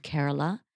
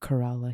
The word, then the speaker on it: Kerala: So from a state called Kerala. Uh-huh. Which is right on the side. – Sometimes we pronounce it Kerala here. – Kerala, Kerala, sorry, that's okay.